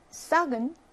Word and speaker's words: sac: sac은 sac 발음하죠. 발음합니다.